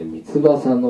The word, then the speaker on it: 御翼: 御翼と書いて三翼の影って読みますが、三翼の影に身を避けます。